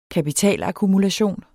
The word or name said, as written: kapitalakkumulation